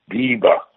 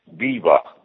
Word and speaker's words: بیبا: چرا؟ اسمش چیه؟ – بیبا. – دیبا. – بیبا. BIBA. – بیبا. – متشکرم. – قربون شما، مرسی. تولد بیبا رو هم تبریک میگیم بهش که اینقدر زحمت کشیده بیبا.